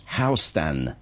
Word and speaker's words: Houston: It says, for Jason, the street's name is pronounced Houston, unlike that of the city of Houston in Texas, which is pronounced Houston. This is because the street was named for William Houston, whereas the city was named for Ham Sam Houston.